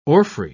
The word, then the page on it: orphrey: British English